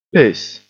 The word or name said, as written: piss